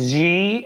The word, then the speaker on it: Xi: Xi, President Xi. – I said Xi, I got it. Awesome. – Xi, like almost a J sound.